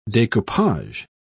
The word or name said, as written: decoupage